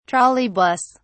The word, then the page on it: trolley bus: British English